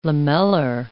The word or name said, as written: lamellar